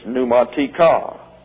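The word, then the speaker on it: pneumatika (πνευματικά): The second use of pneumatikos is the plural dative of advantage in the neuter gender, which looks like this, and it's pneumatika. Pneumatika, pneumatika, accent on the last syllable.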